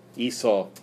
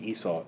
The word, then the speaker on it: Esau: Esau. I like that you gave the Hebrew pronunciation. Esau is the Hebrew pronunciation. Esau is what he's usually called in English and what Shakespeare would have called him.